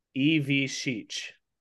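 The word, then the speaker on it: Ivišić: That little S with a little ch thing over it, it's an SH sound, and the C is a CH sound. So it's Zvonimir Ivishich. – Ivishich? – Zvonimir Ivishich. – Ivishich? – Ivishich. Now you don't want to emphasize every single like syllable. You kind of talk quickly through there. Zvonimir Ivishich. But like that's essentially how you say it.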